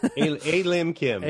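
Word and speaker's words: A Lim Kim: A-Lim Kim, am I saying her name right? – A-Lim Kim.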